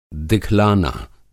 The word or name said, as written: दिखलाना